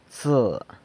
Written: tse

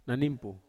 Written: nanimpu

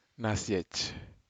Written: nasyej

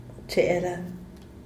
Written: cheela

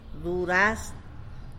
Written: duraz

